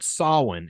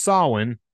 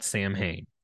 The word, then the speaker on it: Samhain: Anyway, it's pronounced Samhain, not Samhain.